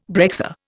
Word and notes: breccia: Indian

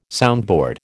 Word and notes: soundboard: American English

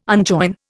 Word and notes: unjoin: Indian

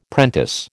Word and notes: prentice: American English

